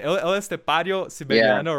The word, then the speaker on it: El Estepario Siberiano: It's like we see so many virtuosos like Marcin or Tim Henson or Ichika Nico or El Estepariano. How do you say it? El Estepario Siberiano, right?